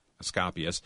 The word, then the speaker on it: Asclepius: Going back to ancient times, in Greek history, more than 300 temples were built to the great physician and healer Asclepius. I probably didn't say that right. – Asclepius.